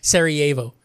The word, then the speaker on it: Sarajevo: As the I had it too, and then you pronounced it twice wrong. Sarajevo. – I pronounced it once wrong because I didn't finish it the first time.